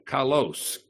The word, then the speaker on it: kalos: The Greek word is kalos, K-A-L-O-S, kalos.